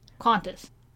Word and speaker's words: Qantas: I think it's spelled Qantas, but they say it because of their awesome accents, Qantas. Like, weird, like, trying to put a U in there, but they don't. Qantas.